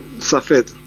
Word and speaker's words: Safed: Perdón, ¿se pronuncia Safed la ciudad? ¿Safed? Safed. Ok.